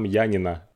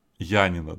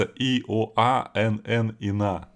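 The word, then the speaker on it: Янина (Ιωάννινα): Янино это у нас тут, а там Янина. Янина, да. Хотя пишется и о а н н и на. То есть как будто, ну, если я по-немецки читаю, то Иоаннина.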